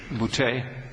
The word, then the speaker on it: Boutte: It's Cajun French, hence the pronunciation. – Boutte? – Boutte.